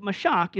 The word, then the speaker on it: mashach: I can't say it like a Hebrew, like a Jew would say it, but mashach is the word.